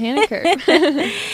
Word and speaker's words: Hannah Kerr: Hannah Kerr.